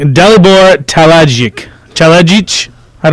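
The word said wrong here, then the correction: Talajić: Talaich, okay. – Yes, the J's are soft.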